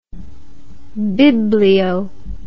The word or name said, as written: biblio